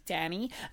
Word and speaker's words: Danny: Yes, hello, my name is Danny. My name, it rhymes with Fanny.